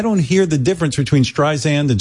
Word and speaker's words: Streisand: You were hitting the second syllable harder. Now you're not. Streisand, like you said.